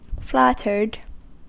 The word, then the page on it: flattered: American English